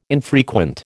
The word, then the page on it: infrequent: American English